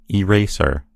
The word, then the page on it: eraser: American English